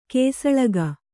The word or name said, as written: ಕುಸಲಕ್ಕಿ